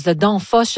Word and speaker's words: Danfoss: Alors, on parle d'une compagnie de logiciels danoise, Danfoss, je ne sais pas si je le prononce comme il faut.